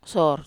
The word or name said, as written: sor